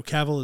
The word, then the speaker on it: Cavill: Did we discover his name is pronounced differently? Cavill? – No, Cavill is right. – Cavill is right. – We used to pronounce it Cavill. – Cavill, because he's British.